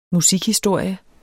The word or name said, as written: musikhistorie